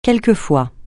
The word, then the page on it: quelquefois: francais